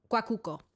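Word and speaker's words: Arepera Guacuco: I think it's wait, is this a typo or is this how it's spelled? – Yeah, no, that's how it's spelled. – Oh, so it's Arepera Guacoco. Guacuco. Yeah. – Yes.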